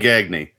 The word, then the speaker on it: Gagne: Because as great as it would be to have a Gagne playing for the Minnesota Twins, his last name was pronounced Gagne. – What an idiot. – He just didn't know how to pronounce it. – Greg Gagne is how you say it.